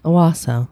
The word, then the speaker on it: Owasso: Do we want to spell that? – Owosso? O-W-A-S-S-O. – Okay, Owosso, yeah.